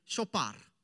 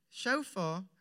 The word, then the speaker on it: shofar: I've heard like shofar and all sorts of different pronunciations. Every time when somebody says a strange one, there was even at a time that shofar was spelled S-H-O-P-H-A-R. And some people said shopar.